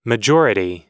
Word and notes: majority: American English

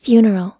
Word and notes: funeral: American English

